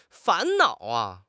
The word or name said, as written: fan2 nao3